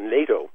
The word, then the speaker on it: NATO: It rhymes with potato, and that's about all I know about it. Let's go down memory lane a little bit here. NATO was responsible for the dismemberment of Yugoslavia.